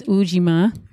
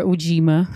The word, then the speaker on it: Ujima: Yes, it's Ujima. – Correct. – Or Ujima, however you want to pronounce it.